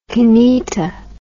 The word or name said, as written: kineto